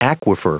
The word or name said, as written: aquifer